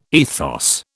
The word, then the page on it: ethos: British English